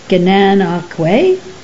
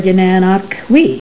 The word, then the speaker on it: Gananoque: I did, though, after bringing a smile to the attendant's face with my awkward pronunciation, learn the two correct ways to pronounce Gananoque. Gananoque or Gananoque.